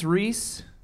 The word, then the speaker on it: Rhys: In English, it's Rhys, like fleece.